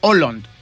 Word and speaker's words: Haaland: A Erling Haaland o Haaland, ¿no? Que es la manera correcta de pronunciarlo.